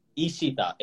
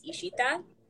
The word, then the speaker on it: Ishita: My first name is Ishita. – It was the E, wasn't it? – The Bengali pronunciation is like you draw out your letters, so it's Ishita.